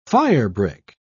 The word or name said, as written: firebrick